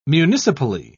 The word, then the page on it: municipally: British English